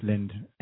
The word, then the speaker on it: Lindh: No, that's Lind. Lind. I think it's pronounced Lind. L-I-N-D-H.